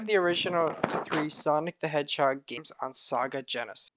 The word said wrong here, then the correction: Sega: It's pronounced Sega.